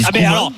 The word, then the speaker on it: Koeman: Okay, dans ce cas-là, Koeman, faut qu'on dise Koeman aussi. – On dit Koeman, bien sûr.